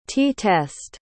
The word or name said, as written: t-test